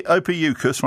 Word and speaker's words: Ophiuchus: So this new star sign, how do you pronounce it? – Ophiuchus, right.